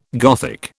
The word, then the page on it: Gothic: British English